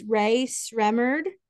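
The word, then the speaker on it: Rae Sremmurd: Black Beatles, and I'm going to possibly mispronounce this, Ray Sremmurd, yeah, you got it.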